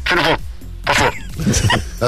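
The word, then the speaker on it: Sanita Pušpure: And did I say it right? So that's the other thing you can do as well. You can rush it. – Yeah. Quickly and confidently. – Sunita Puspore. – That's it.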